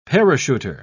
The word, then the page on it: parachuter: British English